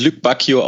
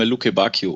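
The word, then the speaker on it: Lukebakio: Ja, da lief gut, dass man mit diesem Tempo spricht er sich tatsächlich Luc Bacchio aus? Ich dachte immer Luke Bacchio. Okay, aber jetzt habe ich wieder was gelernt. – Sehr gut. Er ist ein Belgier und nach der Aussprachetabelle, nach der ich mich richte, tatsächlich wird er dann französisch ausgesprochen.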